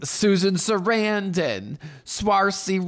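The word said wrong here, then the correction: Saoirse: Ronan. Shersha. How'd she say her name? Shersha Ronan.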